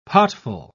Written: potful

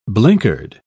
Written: blinkered

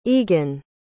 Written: eagan

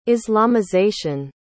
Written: islamization

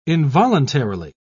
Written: involuntarily

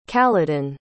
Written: kaladin

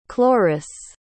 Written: chloris

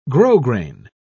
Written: grosgrain

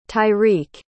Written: tyrek

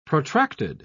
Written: protracted

